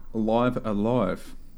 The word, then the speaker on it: Live A Live: Let's talk Live Alive I believe that is the official pronunciation.